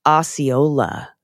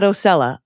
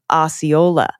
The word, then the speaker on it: Osceola: It's pronounced Osceola, not Ocella, Osceola.